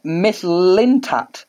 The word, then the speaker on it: Mislintat: We'll talk about Sven Mislintat, which I am reliably informed is perfectly pronounced.